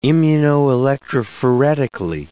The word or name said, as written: immunoelectrophoretically